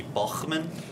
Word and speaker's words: Baughman: If you ask the later, older generations, it would be Baughman, which is a little more of a German tint to it, but Grandpa had to change that, the pronunciation on that for some obvious reasons.